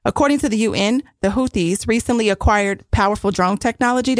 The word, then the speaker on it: Houthis: The Houthis, I think that's how you say it, the Houthis.